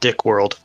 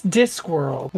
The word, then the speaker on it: Discworld: It's pronounced Dickworld. – No, it's Discworld.